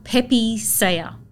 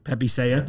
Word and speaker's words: Pepe Saya: So it's called Pepe Saya. – Pepe Saya.